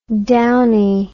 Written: Downie

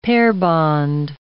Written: pair-bond

